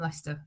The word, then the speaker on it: Leicester: And and I know not to pronounce it Leicester, a Leicester or whatever. You know, we know Leicester.